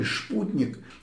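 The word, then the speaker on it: Sputnik: Сама статья Кюммеля под названием «Новый шок, вызванный спутником», напоминает, что у немцев в Федеративной Республике этот самый «шпутник», так слово произносится по-немецки, тогда в 1957-м вызвал ужас, а у немцев в Германской Демократической Республике, наоборот, чувство классовой солидарности.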